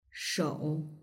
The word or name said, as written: shou